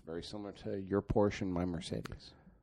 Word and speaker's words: Porsche: By the way, it's a Porsche. It's a two-syllable word.